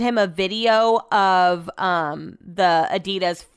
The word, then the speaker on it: Adidas: Like, the pronunciation is Adidas, which is a conversation that I had with Brett when he first moved here.